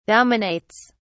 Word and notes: dominates: British English

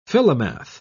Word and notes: philomath: British English

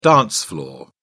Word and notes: dance floor: British English

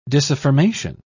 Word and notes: disaffirmation: British English

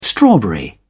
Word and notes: strawberry: British English

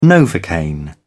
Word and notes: novocaine: British English

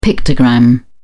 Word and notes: pictogram: British English

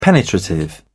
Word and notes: penetrative: British English

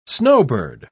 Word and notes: snowbird: British English

